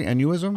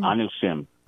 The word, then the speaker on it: Anusim: Is I saying that correctly? Anuism? – Anusim.